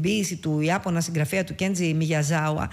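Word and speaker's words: Κέντζι Μιγιαζάουα (宮沢): που κάποιοι τον γράφουν και Μιγιαζάβα, αλλά δεν είναι, γιατί από ό,τι διάβασα είναι Μιγιαζάουα, δεν προφέρεται. – Ναι, είναι αυτό το «βα» το οποίο έχουμε συνηθίσει, ναι. – Από το «γουά» το αγγλικό, αλλά όμως ναι, είναι Κέντζι Μιγιαζάουα.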